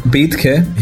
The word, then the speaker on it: Betke: He's called Jacob Bethke.